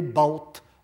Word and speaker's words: baut: mais humble et baut à l'égard de sa femme. Baut, enfin B-A-U-T, je le prononce toujours très mal pour ne pas qu'on croit que c'est beau, le contraire de laine.